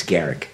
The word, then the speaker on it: Garak: It's not Grack. That's what threw me. It's Garrick.